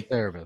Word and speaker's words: Cerebus: Am I saying that right? Cerebus. – Yeah, sounds good to me. – Cerebus. That sounds right.